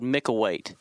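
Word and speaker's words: Micklethwait: It's called Micklewaite. And so it's spelled Micklewaite, and the TH is silent.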